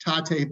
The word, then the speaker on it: Tatte: It's called, I always pronounce this wrong, Tate, T-A-T-T-E. Mark just put a message out there. You're in Boston, you got to go to a Tate bakery.